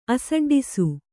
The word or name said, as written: ಅಸಡ್ಡಿಸು